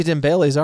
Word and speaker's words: Dembélé: How many Dembélés are there? – Quite a few.